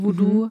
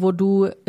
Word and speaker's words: Vodou: Also V-O-O-D-O-O. – Genau, das ist das popkulturelle Voodoo. Mhm. Und das haitianische Voodoo ist V-O-D-O-U. Das wird auch, glaube ich, eher Voodoo dann ausgesprochen oder Voodoo, also mit einer anderen Betonung.